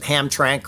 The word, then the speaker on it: Hamtramck: I talked about them two or three weeks ago, an All Muslim Council up there in, I can't pronounce it, Hamtramck or whatever it is, Michigan.